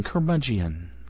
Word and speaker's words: curmudgeon: But it's actually just rolls off the tongue, curmudgeon, curmudgeon. Yeah, it's spelled C-U-R, like a bad dog, mud, M-U-D, and then Geon, G-E-O-N, you know, bad dog rolling in the mud on the planet Geon.